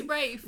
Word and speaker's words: Ralph: Yeah, it's spelt Ralph, but it's pronounced Rafe.